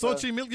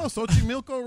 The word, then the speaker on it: Xochimilco: I'm impressed that you pronounced it right. Because my partner would have said Xochimilco.